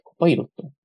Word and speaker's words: Copilot: これ何て読むんでしたっけコパイロット。